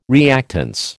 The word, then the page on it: reactance: American English